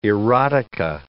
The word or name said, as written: erotica